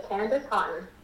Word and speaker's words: Candice Haughton: Candice Haughton.